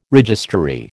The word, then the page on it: registrary: American English